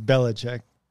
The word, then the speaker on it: Belichick: Mine before you said it wrong, though. – What? – It's Belichick.